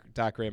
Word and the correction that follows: Dacre: That was mine, and it's apparently pronounced Dacre.